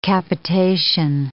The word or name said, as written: capitation